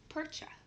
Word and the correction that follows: Perchta: It is because the T comes after the CH. Perchta.